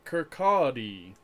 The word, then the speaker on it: Kirkcaldy: Kirkcaldy. Which you would not guess from the spelling of it.